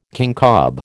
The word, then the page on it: kincob: American English